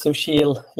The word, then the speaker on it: Susheel: So to start with, my name is Susheel Ladwa. It's a difficult first name. So the easiest way to remember and pronounce it is it's like sushi and eel, put them together.